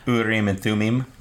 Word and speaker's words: Urim and Thummim: I'm probably not saying that right. – Urim and Thummim, yeah.